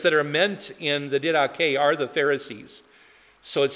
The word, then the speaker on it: Didache: I have a quote here from the early Christian document called the Didache, which looks as if it's spelled the didache, as if it rhymes with headache, but it's pronounced Didache, which is the acts of the or the teaching of the apostles, rather.